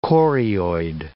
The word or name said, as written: chorioid